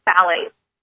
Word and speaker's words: phthalates: and those are called phthalates. And it's spelled with a P-H-T-H, which throws a lot of people because it's just pronounced with a T-H.